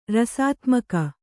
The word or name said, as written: ರಸಾತ್ಮಕ